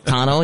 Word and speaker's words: tonneau: Yeah, I mean, from the guys who have one, I've always heard it pronounced tonneau cover. – It is tonneau, yeah.